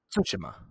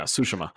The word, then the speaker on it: Tsushima: which I think that's the correct way to pronounce it. I think that's the way Sucker Punch pronounces it, is Ghost of Tsushima. – Yeah, and it's Tsushima. – Tsushima. – It's like, yeah. We have a Japanese roommate right now, so she is schooling me on how to say it.